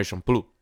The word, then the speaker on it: Champloo: Yeah, Samurai Champloo.